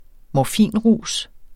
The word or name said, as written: morfinrus